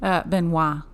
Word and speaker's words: Benoit: is Chris Benoit. Benoit? – Spell it. – B-E-N-O-I-T. – Benoit. – Benoit. Cool. – I think so. – I am too... – If my boyfriend's out there... Let me... – Hello? – I have a question for you. – Yeah, okay. – Kelly and I want to know how you pronounce this name. B-E-N-O-I-T. – Benoit. – Okay, that's what I thought. Thank you. Love you. Bye. – Thank you. Bye. – That was fun. So it's Benoit.